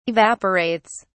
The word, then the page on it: evaporates: British English